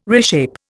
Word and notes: reshape: Indian

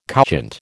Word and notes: couchant: American English

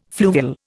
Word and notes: fluvial: Indian

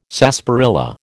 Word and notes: sarsaparilla: American English

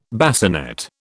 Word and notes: bassinet: British English

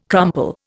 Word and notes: crumple: Indian

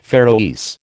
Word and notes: Faroese: American English

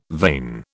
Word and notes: vane: British English

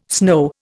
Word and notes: snow: Indian

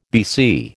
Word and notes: BC: American English

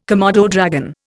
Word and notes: komodo dragon: Indian

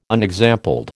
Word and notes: unexampled: American English

